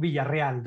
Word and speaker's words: Villarreal: So my name in Spanish is Alberto Villarreal.